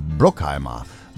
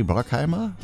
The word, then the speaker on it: Bruckheimer: Dann der US-amerikanische Film- und Fernsehproduzent Jerry Bruckheimer. Bruckheimer? Ich glaube, das wird ja amerikanisch. Da wird ja das U wie A ausgesprochen. Ne? Jerry Bruckheimer, würde ich mal so sagen.